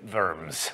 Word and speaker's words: Worms: So in 1521, Luther was called to defend his ideas before the Holy Roman Emperor Charles V at the Imperial Diet of Worms, or in German, Worms.